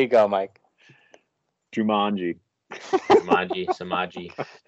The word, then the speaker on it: Samaje: It spells exactly how you say it. Samaje.